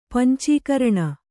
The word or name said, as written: ಪಂಚಕರ್ಣ